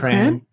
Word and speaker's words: Prahran: Is that close to Prahran? – Yeah, not too, like 10 minutes from there. Yeah. – Am I saying it right? Prahran. Is it Prahran? – Prahran, yeah.